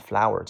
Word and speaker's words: flour: 下个单词 flour，这个单词和花儿 flower 那个单词的拼写不一样，但是发音是一样的。 flour 叫面粉的意思。